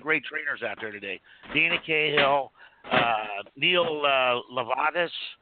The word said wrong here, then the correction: Lubovitz: I think he does dynamic sales. – Lubovitz. – Yeah. – Lubovitz. – He's been a guest here a couple. – Pronounce his last name, please. – Lubovitz. Yeah, okay. – Lubovitz.